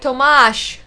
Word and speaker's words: Tomáš: And apparently, Tomáš, as you said it, it's pronounced more like Tomáš.